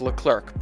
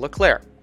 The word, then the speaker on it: Leclerc: Finishing third in the Drivers' Championship was Ferrari driver Charles Leclerc, spelled Charles Leclerc, but apparently pronounced Charles Leclerc.